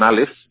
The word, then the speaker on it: Aleph: En las antiguas lenguas semíticas el Aleph es una oclusión de la glotis y se pronuncia solo con la vocal que acompaña, es decir, un alef sería como una letra muda, prácticamente como una H.